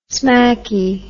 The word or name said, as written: smacky